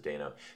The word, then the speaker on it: Dano: I know Paul Dano could have done, or Dano, I think he pronounces it Dano, could have done, like, something different with this, and I maybe would have liked to see that.